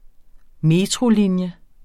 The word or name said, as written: metrolinje